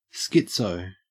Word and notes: schizo: Australian English

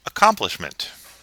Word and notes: accomplishment: American English

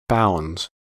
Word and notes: bounds: American English

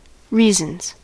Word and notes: reasons: American English

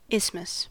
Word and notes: isthmus: American English

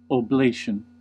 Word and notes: oblation: American English

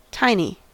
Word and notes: tiny: American English

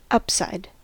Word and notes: upside: American English

